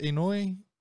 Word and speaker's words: Inoue: Is it Inoue? – Correct.